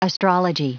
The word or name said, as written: astrology